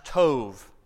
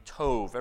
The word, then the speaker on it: tov: There's a Hebrew word that's pronounced tov, it's been kind of taught to me, tov. Everyone say this together, tov. T-O-V, tov.